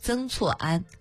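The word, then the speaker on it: 曾厝垵: 南宋末年，北宋政治家曾公亮的九世孙曾光绰携家眷避居于此，谓之曾氏处此庵，闽南读音念作曾错庵，错是房子，庵是地名，那三个字翻译过来就是老曾家的住处。明初厦门城建起来之后，曾错庵因扼守厦门港南部咽喉，所以成了军事要冲。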